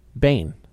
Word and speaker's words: Bain: I don't know if I'm saying it right. Ming Bane. – How do you spell it? – M-E-N-G. – Yeah. – New word. – Right. – B-A-I-N. – Bane. – Bane. So I said. – I thought you said bang.